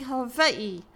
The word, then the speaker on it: Hawaii: Okay, y'all are gonna hate this, but my biggest pet peeve in this movie is how they keep mispronouncing Hawaii. The W in is pronounced like a V. That's the way the Kanaka Maoli, the Kama'aina, the locals pronounce it.